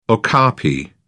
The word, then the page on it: okapi: British English